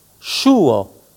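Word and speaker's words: ŝuo: S circonflexe se prononce ch, comme dans chat. Exemple, chouo, chaussure.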